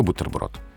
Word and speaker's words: бутерброд: Но пока только твердо, только бутерброд.